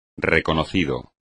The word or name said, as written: reconocido